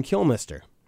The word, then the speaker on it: Kilmister: referenced him as Ian Kilmister. – Okay. All right, so that's the official way to pronounce it apparently from here on out.